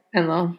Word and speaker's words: Enlil: Basically talks about Enki and Enlil. Sorry. – Enlil. – I always say that wrong.